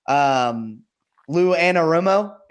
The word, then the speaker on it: Anarumo: Lou Anarumo, am I saying that correctly? – Yeah.